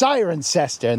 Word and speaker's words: Cirencester: Well, could you tell me the way to Cirencester? And you'd go, no, you idiot. It's pronounced Cirencester.